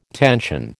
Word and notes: tension: American English

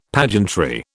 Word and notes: pageantry: British English

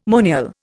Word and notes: monial: Indian

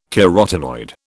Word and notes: carotenoid: British English

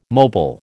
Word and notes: mobile: American English

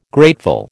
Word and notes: grateful: American English

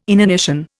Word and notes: inanition: Indian

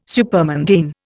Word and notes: supermundane: Indian

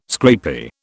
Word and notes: scrapie: British English

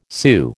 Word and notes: Sioux: American English

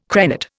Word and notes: crenate: Indian

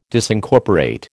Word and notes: Disincorporate: American English

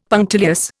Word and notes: punctilious: Indian

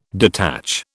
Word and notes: detach: British English

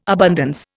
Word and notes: abundance: Indian

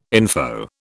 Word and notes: info: British English

